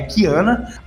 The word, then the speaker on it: Qiyana: então, eu vou deixar aqui, eu vou falar aqui, né, que Kiana, a pronúncia corretas, né, é Kiana, a terra de onde ela veio é Istal, né, I-X-T-A-L, Istal, e a cidade dela é Ishaokan, que até o pessoal ficou brincando, ah, Ishaokan do Mortal Kombat.